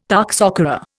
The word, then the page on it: toxocara: Indian